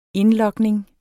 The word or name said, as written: indlogning